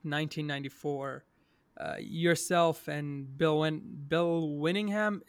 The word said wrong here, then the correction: Willingham: is that how we say his name? Because I'm sorry if we butcher his name. Yeah, Bill uh, Willingham.